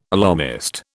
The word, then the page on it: alarmist: British English